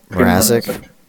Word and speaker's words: Mrazek: Their starting goaltender, what's his name? Mazrik. Marazek. I don't remember. I don't know how to pronounce that. Petr. – Oh, Mrazek.